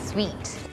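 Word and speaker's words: suite: sweet sweet room sweet เนี่ยมันจะออกเสียงเหมือน sweet ที่แปลว่าหวานเลยค่ะ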